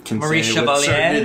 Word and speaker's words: Maurice Chevalier: Named Maurice Chevalier. I hope I pronounced that right. – Maurice Chevalier. That you almost did not, but you got it.